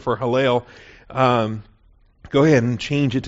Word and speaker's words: Heylel: Hallel, H-E-Y-L-E-L, and that second E is a long E. That's why I put the macron over the top of that. Hallel, H-E-Y-L-E-L, macron over that second E, Hallel.